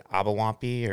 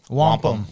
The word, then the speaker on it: wampum: Give me some beads of, was it Abawampi, or what, how do you say that? – Wampum.